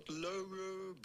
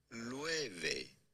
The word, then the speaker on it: Loewe: How do you go about pronouncing this name? It's not Loewe, but rather Loewe.